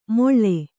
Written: ಮೊಳ್ಳೆ

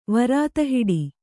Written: ವರಾತಹಿಡಿ

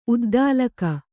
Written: ಉದ್ದಾಲಕ

